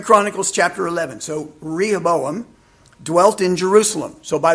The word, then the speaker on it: Rehoboam: You're pronouncing it just like I've pronounced it all my life. Rehoboam. Rehoboam. I've asked him three or four times, what are you saying? And he finally pointed to it. And I went, oh, Rehoboam. And he just had this look of utter disgust.